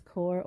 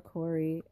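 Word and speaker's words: Kore: It is Cor or Corey. And actually, Corey is the Greek pronunciation of the name, which is the original pronunciation or origin of the name anyway.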